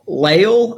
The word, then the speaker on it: Lael: And a local man named Ralph Lael, I believe is how it's pronounced. It's spelled L-A-E-L.